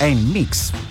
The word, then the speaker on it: NMIXX: El mix puede ser, ¿sí? Porque es N-M-I-X-X. Mix, en mix, ¿eh?